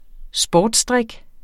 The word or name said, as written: sportsdrik